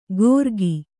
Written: ಗೋರ್ಗಿ

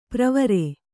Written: ಪ್ರವರೆ

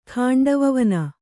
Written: ಖಾಂಡವವನ

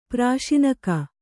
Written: ಪ್ರಸೂನಕ

